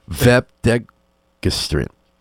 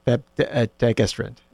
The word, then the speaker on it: Vepdegestrant: I know it's a hard G. Vepdegestrant. – Vepdegestrant, exactly. – One more time. – Vepdegestrant. – Degestrant, okay, the accent is deg.